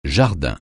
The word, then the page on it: jardin: francais